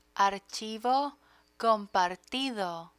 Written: archivo compartido

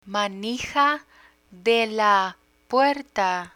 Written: manija de la puerta

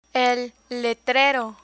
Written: letrero

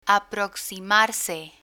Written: aproximarse

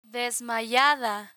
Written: desmayada

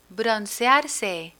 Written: broncearse

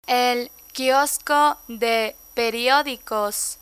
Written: el quiosco de periodicos

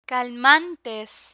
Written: calmantes

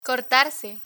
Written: cortarse

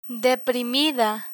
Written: deprimida

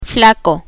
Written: flaco

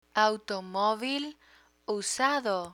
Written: automovil usado

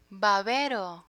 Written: babero

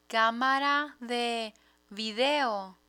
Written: camara de video

